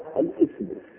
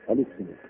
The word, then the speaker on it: الاسم: لاحظتم أنه قال الاسم، نطق هذه الكلمة ليس هكذا، الاسم الاسم يعني دون همزة، لأن يعني هذه همزتها همزة وصل فلا ينطق بها وإنما تكتب ولا تنطق.